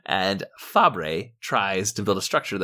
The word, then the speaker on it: Fabre: Fabre, F A B R E. I'm glad you pronounced it first. I was going to go there, probably Fabre.